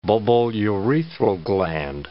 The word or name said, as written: bulbourethral gland